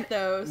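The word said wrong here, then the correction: Mytho: Muto. It's pronounced Muto. Muto.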